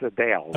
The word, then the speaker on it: Adele: Uh, it's Adele.